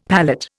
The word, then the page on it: palette: Indian